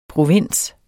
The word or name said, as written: provins